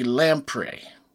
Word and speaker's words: lamprey: There's the hagfish and the lamprey. – Right. – L-A-M-P-R-E-Y, however you pronounce that. Lamprey? – Yeah. – Okay. – That sounds good to me.